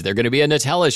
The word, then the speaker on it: Nutella: And Ferrero, which makes those, also makes Nutella, which I've heard some people pronounce Nutella. How do you pronounce it? – Nutella.